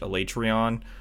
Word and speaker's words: Alatreon: I think it's pronounced Alatreon. The people in game pronounce it Alatreon, which is really dumb. So we call him Alatreon.